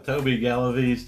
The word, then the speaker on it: Toby Galaviz: Oh yeah, Toby Galaviz, T-O-B-Y, G-A-L-A-V-I-Z. – And mine's Aaron Carolision, A-A-R-O-N K-A-R-O-L-I-S Z-Y-N.